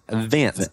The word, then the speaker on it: Vence: Vence, V-E-N-C-E.